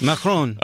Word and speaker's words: Macron: It's like Macron. – Okay, that's good. – Macron.